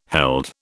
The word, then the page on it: held: British English